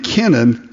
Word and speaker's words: Kenan: It's really the name Kenan, K-E-N-A-N. It's not like Cain.